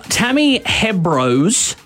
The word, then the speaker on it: Hembrow: Now, Tammy Hembrow's her name.